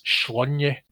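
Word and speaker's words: Sláine: Though the strip famously mentions that Slain is pronounced Schlunye, but he's most definitely Slain in this.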